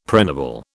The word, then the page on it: pregnable: British English